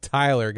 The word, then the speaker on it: Thyler: It's spelled Thyler, don't ask me why, but it's pronounced Tyler.